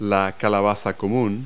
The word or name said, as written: pumpkin